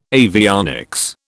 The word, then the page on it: avionics: British English